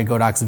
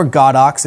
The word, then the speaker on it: Godox: But I would say most of the time I'm just using my Godox V1, or Godox if you want to get back to the official real pronunciation, I guess, of the company.